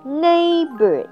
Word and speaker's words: neighbor: 下一个字我们要留意的呢是neighbor， neighbor， E-I 在这边发 A的长音， G-H 在这边是 silent word，不发音， neighbor，高音在第一段。